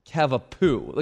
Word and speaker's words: cavapoo: It's not pronounced cavapoo. Like the emphasis is most definitely not on the poo there.